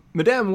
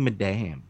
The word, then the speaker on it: Madame: Madame Web plot leak. – Just say Madam. Stop saying Madame. – Is it Madam? – It's Madam. – It's Madam Web.